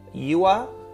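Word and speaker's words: Ua: That tribe is apparently called Yua.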